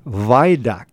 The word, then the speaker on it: Vojdak: And your last name, Paul, I'm pronouncing it as Vojdak. Does that sound correct? – Vojdak. – Yeah, Vojdak. – Yeah, Vojdak. That would be the proper Polish pronunciation.